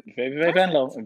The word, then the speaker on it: VVV Venlo: VVV Venlo.